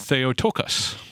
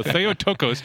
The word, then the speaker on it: Theotokos: Which is why we can call her the Theotokos.